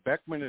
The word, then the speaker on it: Beckmann: Beckman is spelled B-E-C-K-M-A-N-N.